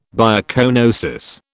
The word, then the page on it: biocoenosis: British English